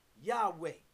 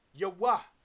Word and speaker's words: Yahweh: Now, Jehovah 3068, also pronounced, some of the scholars, Yahweh. Some of the other scholars pronounce that Yahuwah. Some pronounce it Yahuwah.